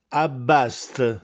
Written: Abbastè

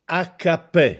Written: Accappè

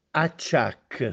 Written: Acciàcche

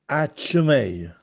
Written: Accemè

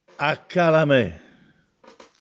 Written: Accalamè

